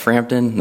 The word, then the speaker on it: Frampton: For the record, my name is Wyatt Frampton. That's spelled F-R-A-M-P-T-O-N.